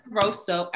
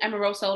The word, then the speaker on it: Amoroso: Amoroso is like Italian or whatever, but it's people think it's, they spell it with the A, they spell it with like a E. I'm like, it's Amoroso, like the Italian bread right there, okay?